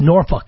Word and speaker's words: Norfolk: By the way, the correct pronunciation of this town is not Norfolk. It's Norfolk.